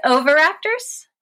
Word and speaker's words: oviraptors: Am I saying that right? – Yeah, it's oviraptors. – Oviraptors.